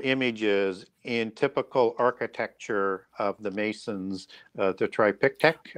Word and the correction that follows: triptych: I think it's called. Right. Triptych, I think it is more accurately. – Yeah. – Uh-huh. Butchered it the first time, but triptych, as I recall.